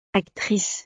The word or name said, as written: actrice